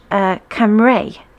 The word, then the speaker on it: Cymru: Wales is pronounced in Welsh, Cymru. It's spelled C-Y-M-R-U and pronounced Cymru.